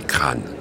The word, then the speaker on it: Craon: Pour la basse, si j'y suis depuis longtemps, c'est la journée de Craon, de la chanson de Craon qu'il faut prononcer crâne.